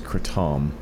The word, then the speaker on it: kratom: The podcast is about Kratom, or Kratom. How do we call it? – So actually, I think the proper pronunciation, which I don't use, is Kratom.